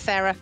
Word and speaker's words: Sara: It's Sara. It's fine. It's Yay! – Not Sara.